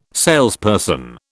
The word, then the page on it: salesperson: British English